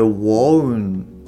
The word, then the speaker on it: won't: で発音としては、えー、 won't won't っていうふうにまあ、最後に t の音があの綴り上